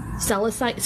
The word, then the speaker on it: Celestite: Celestite. So what? How the fuck do you pronounce it, guys? You guys are the way you did.